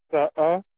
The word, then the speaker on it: Saha: Le SAA, S-A-H-A.